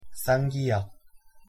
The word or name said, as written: ㄲ